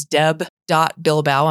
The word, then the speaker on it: Bilbao: My email is deb.bilbao. I'm going to spell that because nobody can. B-I-L-B-A-O at slcc.edu.